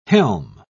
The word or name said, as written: helm